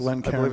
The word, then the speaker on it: Glencairn: Glencairn glasses. – Did I pronounce that right? – Right. Me? Yeah. – And this is C-A-I-R-N.